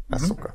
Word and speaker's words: Ahsoka: Ahsoka. Így kell kiejteni, ugye? Mhm. – Ahsoka.